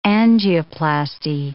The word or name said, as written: angioplasty